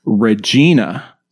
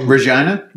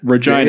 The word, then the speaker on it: Regina: And then one that literally I just found out about last week, Regina style pizza, which is also in Canada. – Oh, Regina? – Regina, yeah, sorry.